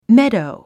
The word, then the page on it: meadow: médou; メドウ